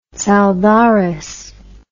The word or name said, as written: tsaldares